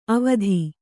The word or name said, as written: ಅವಿಧಾ